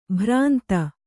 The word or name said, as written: ಭ್ರಾಂತ